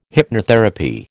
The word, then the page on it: hypnotherapy: American English